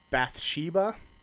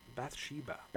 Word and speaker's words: Bathsheba: I'm gonna butcher this. Bathsheba. – Bathsheba. – Bathsheba. B A T H. Bathsheba. Uh, B A T H S H E B A.